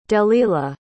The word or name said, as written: delila